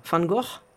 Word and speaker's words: Van Gogh: Ja, so, so heißt er auch, aber der wird anders ausgesprochen, du. Wie denn? Van Gogh.